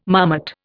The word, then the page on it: marmot: Indian